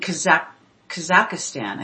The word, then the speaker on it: Kazakhstan: There are cities, or at least a major city, from what I understand, in Kazakhstan, I think is how you say it. – Yep.